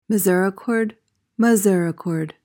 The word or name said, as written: misericord